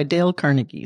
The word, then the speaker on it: Dale Carnegie: How to Win Friends and Influence People by Dale Carnegie.